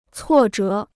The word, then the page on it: 挫折: cuò zhé